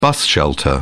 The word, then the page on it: bus shelter: British English